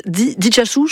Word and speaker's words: Itsasu: Alors je tente sur la prononciation, c'est la cerise d'Itsasu, je crois qu'on dit comme ça, le X. – C'est ça.